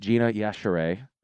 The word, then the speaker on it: Gina Yashere: I'm here with my guest, GINA YASHERE.